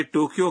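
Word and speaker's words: ٹوکیو (東京): یہ ٹوکیو کا درست جاپانی تلفظ ہے۔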